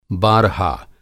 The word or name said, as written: बारहा